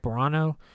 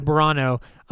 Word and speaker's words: Burano: Burano. – That's how I'd pronounce it. – Yeah, Burano.